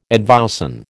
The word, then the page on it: advowson: American English